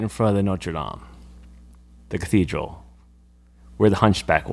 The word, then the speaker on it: Notre Dame: Notre Dame.